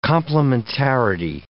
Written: complementarity

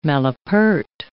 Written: malapert

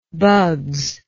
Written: bugs

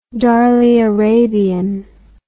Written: Darley Arabian

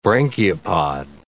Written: branchiopod

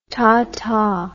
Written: taata

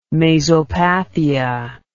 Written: mazopathia

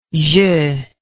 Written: jeu

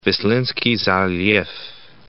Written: Vislinski Zaliv

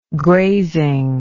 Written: grazing

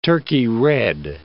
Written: Turkey red

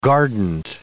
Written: guardant

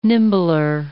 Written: nimbler

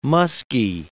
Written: muskie

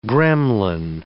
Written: gremlin

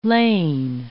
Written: lane

